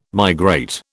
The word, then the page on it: migrate: British English